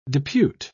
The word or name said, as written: depute